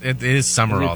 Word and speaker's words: Sumrall: Is that how you say it? He's new. Sumrall. – I know, it's weird. It is Sumrall, though. – Is it pronounced Sumrall? – Yeah. – I feel like there's a letter missing. – It is. It's not spelled right, but that's how it's pronounced.